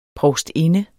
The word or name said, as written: provstinde